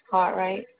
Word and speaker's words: Cartwright: Last name is Cartwright, CARTWRIGHT.